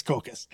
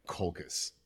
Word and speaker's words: Colchis: it's, Col- it's Colchis. Colchis is such a better way of saying it. Anyway, Colchis. Anyway, the H is silent because English is a fun language.